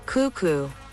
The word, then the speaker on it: cuckoo: En inglés también, solo que allí la sílaba acentuada es la primera y por eso dicen cuckoo.